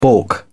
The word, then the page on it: baulk: British English